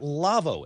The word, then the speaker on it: Lawo: And our show is brought to you in part by the folks at Lavo, L-A-W-O, pronounced Lavo.